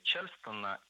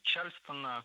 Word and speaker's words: Чарльстон: Чарльстон – это неверно. Правильное название – Чарльстон. Ударение в этом слове должно падать на второй слог. Это слово произошло от названия американского города. И город, и танец в русском языке имеют ударение на втором слоге – Чарльстон.